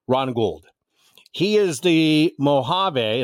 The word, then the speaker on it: Mojave: Is it actually Mojave? It looks like Mojave. Mojave. Okay. Generally, you see it spelled with the J, but apparently they anglicized it once upon a time.